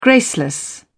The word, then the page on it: graceless: British English